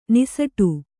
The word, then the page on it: ನಿಸಟು: niśaṭau